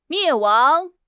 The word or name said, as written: 灭亡